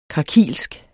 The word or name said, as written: krakilsk